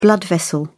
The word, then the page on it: blood vessel: British English